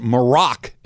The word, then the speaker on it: Maroc: Uh, Golan's Shahibi says, Hey, just an FYI, Morocco is abbreviated MAR because it's known as Maroc, M A R O C, in French.